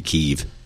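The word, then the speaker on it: Kyiv: It's American Dang. School children pronunciation. It's actually Kiev.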